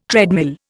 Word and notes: treadmill: Indian

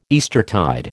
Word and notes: Eastertide: American English

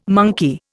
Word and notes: monkey: Indian

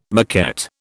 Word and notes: maquette: British English

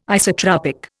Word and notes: isotropic: Indian

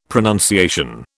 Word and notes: pronunciation: British English

